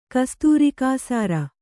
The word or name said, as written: ಕಸ್ತೂರಿಕಾಸಾರ